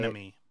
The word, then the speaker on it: Bieniemy: we really have never seen his last name's Bieniemy. Is that how you say it? – Bieniemy with a B. – Bieniemy? – Yeah. – Okay, Bieniemy. – It's not an enemy. Yeah. It's not Finding Nemo. – It's a Bieniemy.